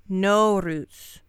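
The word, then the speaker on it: Noruz (نوروز): Norwoots. No, wait. Hold on. I wrote this one out phonetically because it really screwed up my brain. Noruz. Yes, Noruz,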